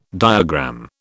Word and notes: diagram: British English

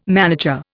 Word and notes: manager: Indian

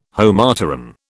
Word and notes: Homopteran: British English

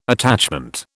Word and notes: attachment: British English

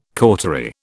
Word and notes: cautery: British English

